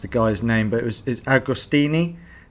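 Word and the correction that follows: D'Agostino: Is that right? – Dom Agostino. – Yeah, okay. I was close enough.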